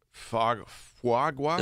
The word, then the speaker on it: foie gras: Foie gras? – Does get-go have foie gras? – Is that how you say it? Uh-huh. Foie gras.